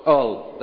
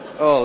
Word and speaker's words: Hull: I'm told I didn't pronounce it properly at all in the first service. Isn't that right, Andrew? Hull. There you go. Hull. Let's get it right this morning.